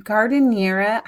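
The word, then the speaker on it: giardiniera: And then Monday night, I planned Italian sandwiches with your roasted gardeniera. I always feel like I'm saying that wrong. – Yeah. – Among many other things. No, is that correct? – That's it.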